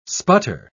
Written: sputter

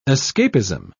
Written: escapism

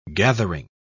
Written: gathering

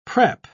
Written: prep

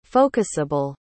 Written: focusable

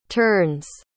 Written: ternes

